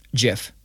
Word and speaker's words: GIF: GIF or JIF? – JIF. – Fuck you. – Dude, that's the real fucking way to pronounce it.